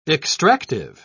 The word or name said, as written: extractive